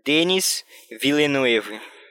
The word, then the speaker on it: Villeneuve: Enquanto isso, eu vou ensinar vocês a pronunciar o nome do diretor. Denis Villeneuve.